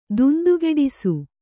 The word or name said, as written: ದುಂದುಗೆಡಿಸು